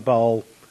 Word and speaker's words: Baal: Sorry, I always call him Baal, a long A.